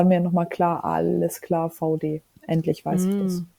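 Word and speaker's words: VAUDE: Ist eine Marke einfach, V-A-U-D. – Very Deutsch. Ach so, nee, Vod. Ach so, ja, aber VD wird die ausgesprochen, ja.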